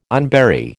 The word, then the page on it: unbury: American English